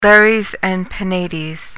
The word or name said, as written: lares and penates